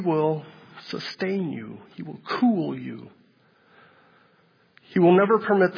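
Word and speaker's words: cool (כּוּל): It's the word K-U-L. It's pronounced cool. I thought, oh, that's cool.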